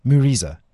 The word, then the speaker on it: Mureza: So just to be clear, Muriza, how do you say it? Muriza. – Muriza. – Muriza. Muriza. Yeah. M-U-R-E-Z-A.